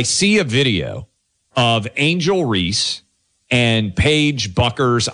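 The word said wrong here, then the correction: Bueckers: I think that's how you pronounce her name. I'm not great, to be fair. Buckers, that's right, right? And we got producer Greg, who's a big UConn. Beckers, sorry. Paige Beckers.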